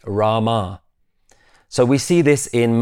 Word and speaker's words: Rama: In Sanskrit, it would be pronounced Rama, with the last letter fully pronounced.